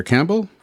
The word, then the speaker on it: Campbell: Dr. Campbell, welcome to Doc Talks.